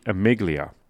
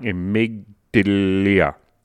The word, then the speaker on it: amygdala: Now, the next part of the brain that gets energized, that would be called the amygdala. And I pronounced it wrong, amygdala.